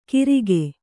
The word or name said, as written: ಗಿರಿಕೆ